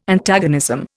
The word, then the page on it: Antagonism: Indian